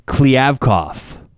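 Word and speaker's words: Kliavkoff: So I looked up the pronunciation, by the way. Klivkov. – I think you got it. – Klivkov for me a couple times.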